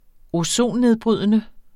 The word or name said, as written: ozonnedbrydende